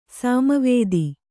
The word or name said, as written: ಸಾಮವೇದ